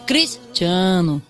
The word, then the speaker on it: Cristiano: Uno es ponerte triste en la sílaba tónica. O sea, por ejemplo, si tú tienes que decir Cristiano Ronaldo, tienes que decir Cristiano. Tienes que ponerte triste justo donde está el acento.